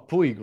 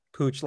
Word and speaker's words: Puig: You call him Puig, right? I called him Puig, but you said, how do you pronounce his name? – Pooch, like Pooch. Like a dog.